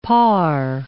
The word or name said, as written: parr